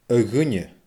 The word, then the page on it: a dhuine: Scottish Gaelic